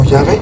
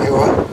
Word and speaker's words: Yahvé: Y ellos pronunciaban algo como Yahvé, no como Jehová.